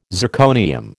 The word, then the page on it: zirconium: American English